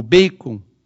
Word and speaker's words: Bacon: E Rogério Bacon, ou Bacon, na pronúncia certa,